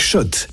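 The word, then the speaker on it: Schott: Jouer et gagner sur RMC avec Shot, la marque iconique new-yorkaise depuis 1913. Oui, Shot. Et pas Scott, ça fait des années qu'on prononce mal la marque.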